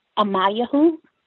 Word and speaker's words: Amanyahu: Yes, my name is Amayahu. You can call me Maya. – Are you saying Amayahu? Spell it. – Yes. A-M-A-N-Y-A-H-U.